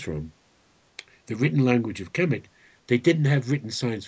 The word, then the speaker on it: Kemet: It's spelled Khemet, Khemet. Anyway, it's Kemet, Kemet.